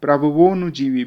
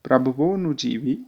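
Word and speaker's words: प्रभवोऽनुजीवी: प्रभवोनुजीवी भी अगर संधि विच्छेद कर दिया जाए तो होगा प्रभवः अनुजीवी भी, परंतु यहाँ पर संधि के साथ उच्चारण करना होगा तो उसमें प्रभवो के बाद अ की ध्वनि नहीं आएगी बल्कि सीधे प्रभवोनुजीवी भी इस तरह से उच्चारण होगा।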